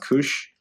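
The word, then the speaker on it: kuš!: Kush Comics called it's spelled K U S and it's pronounced Kush.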